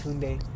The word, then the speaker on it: Tunde: Usually people say Tunde or Tunde or that's Tunde, Tunde. I am not changing the name for you. It's Tunde.